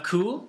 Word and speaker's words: cul: And then we have the French word for arse, which is spelt C-U-L, which is pronounced cool. – Cool. – If you notice, there's slightly less stress on the L at the end, so it's cool.